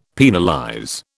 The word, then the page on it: penalize: British English